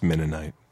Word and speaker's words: Mennonite: The Moonanites were going to... They had set up a device... – It's pronounced Mennonite.